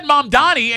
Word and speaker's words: Mamdani: I just said Mamdani, and you kept saying it wrong. – Mamdani. – M-A-M.